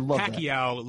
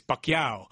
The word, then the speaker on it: Pacquiao: It's not Pacquiao, like everyone says, most English speakers say. It's Pacquiao.